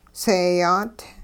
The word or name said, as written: seeyaat